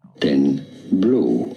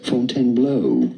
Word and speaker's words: Fontainebleau: So this is sort of tricky when companies do this kind of thing, like Fontainebleau. Fontaine, meaning fountain in French, and then bleu, E-A-U, forming the sound O, of course, in French. It'd be fine in English to say it as Fontainebleau.